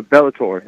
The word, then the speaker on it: Bellator: Am I saying that correctly? Bellator or Bellator? – Yeah, it's a Bellator. Yeah, you're correct.